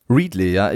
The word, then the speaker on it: Readly: Spricht man das so aus? – Readly, ja.